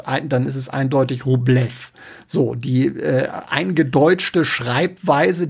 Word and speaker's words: Rublev: Ähm, ich stoße immer öfter auf die Frage, ob es Andrei Rublev oder Andrei Rubilov heißt.